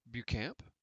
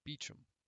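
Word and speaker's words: Beauchamp: He had, in fact, pronounced the name in correct French, though I had given it the common English pronunciation of Beecham.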